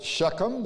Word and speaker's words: Shechem: His brethren went to feed their father's flock in Shechem. If you want to pronounce it Shechem, that's okay.